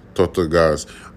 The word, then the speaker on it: Dry Tortugas: In Inglewood to Tokolaski and dry Tortugas. In Spanish, I think they say Tortugas. So that's the proper way to say it.